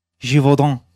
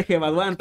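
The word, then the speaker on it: Gévaudan: ¿Cómo se pronuncia? – Givaudan. La bestia de Gevaudan, tal como lo dijo.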